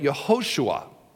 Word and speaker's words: Yehoshua: The longer form of Yeshua is, if I can say this right, Yehoshua, or we say it, Joshua.